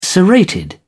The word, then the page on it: serrated: British English